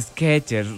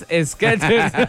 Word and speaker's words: Skechers: La pronunciación es Skechers. Recuerda, Henry es Skechers. – Señores, y después duda otra vez y consulta otra vez y otra vez, le repito que es Skechers.